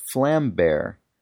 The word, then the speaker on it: Vlambeer: Vlam is pronounced close to flam, and beer is pronounced bear. So it's pronounced flam bear, which is easy to remember since it means flame bear.